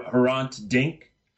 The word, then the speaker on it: Hrant Dink: if I'm pronouncing the name correct, uh, Her- Herant Dink. Yes.